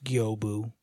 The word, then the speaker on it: Gyoubu: I kept telling you and Jess it was Gyobu because it was G-Y-O-U-B-U or whatever. – Mm-hmm. – No, it's pronounced Gyobu.